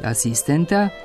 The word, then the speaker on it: asistenta: Ako je verovati priručnicima, moglo je da se kaže i asistenta i asistenta, znači sa kratkouzlaznim, a ne sa kratkosilaznim akcentom. Ali sad ne može. U rečniku srpsko-hrvatskog književnog jezika Matice Srpske piše da su dozvoljena oba oblika, a u novom matičinom rečniku srpskog jezika, kao i u rečniku stranih reči, zabeležen je samo oblik sa kratkouzlaznim akcentom na drugom slogu koji tu ostaje u svim oblicima.